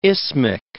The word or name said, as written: isthmic